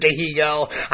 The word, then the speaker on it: Dihigo: It's not Martin Dihigo. I've seen it spelled Dihigo. The correct pronunciation is Martin Dihigo.